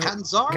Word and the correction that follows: Catanzaro: Catanzaro. I never remember how to pronounce it.